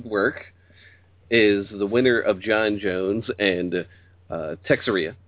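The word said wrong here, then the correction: Teixeira: Am I saying that right? – Tajira. – I say it. God damn me. But Tajira, the winner of that fight, will go on and fight Gustafsson.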